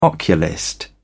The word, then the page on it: oculist: British English